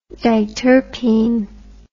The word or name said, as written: diterpene